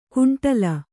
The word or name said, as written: ಗುಂಡಲ